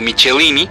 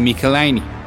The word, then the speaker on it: Michelinie: De entrada quiero pedir disculpas por las veces que he pronunciado Michelini, cuando en realidad la correcta pronunciación es más cercana a un Michelini.